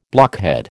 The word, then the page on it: blockhead: American English